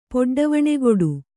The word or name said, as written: ಪೊಡ್ಡವಣೆಗೊಡು